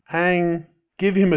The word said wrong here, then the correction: Hwang: Oh, have I been saying it wrong? – That's Huang. Okay. No. Yeah, well, a little bit. Everybody says it differently. It's Alex T. Huang. – Huang. – H-W-A-N-G. – Okay, yes. So the H is silent.